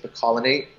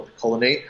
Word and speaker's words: picolinate: But a better one is zinc picolinate or picolinate, however you want to spell say it. It's spelled P-I-C-O-L-I-N-A-T-E.